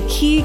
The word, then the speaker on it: Ki: And it turns out that they have another wireless charging standard for kitchen appliances at much higher wattages, and it is spelled KI. and it is pronounced. – All you have to do is look for the Key Cordless Kitchen logo and know that you're protected by the Wireless Power Consortium's trusted industry-leading technology.